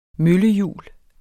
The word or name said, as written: møllehjul